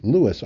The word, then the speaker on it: Louis: Actually, Louis Armstrong, that's how he pronounced his own name, and lots more.